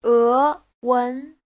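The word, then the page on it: ewen: e2wen2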